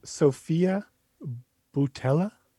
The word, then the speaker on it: Sofia Boutella: Uh, so Sofia Boutella sounds right.